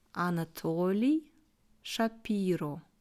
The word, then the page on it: Anatoly Schapiro: slow